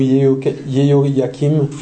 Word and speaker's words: Yehoyakim: C'est Joachim ou Yehoyakim. Voilà. Suivant comment on écrit en français ou dans notre langue, le nom hébraïque, en fait. C'est un petit peu comment on considère qu'on doit le prononcer.